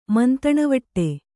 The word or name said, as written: ಮಂತಣವಟ್ಟೆ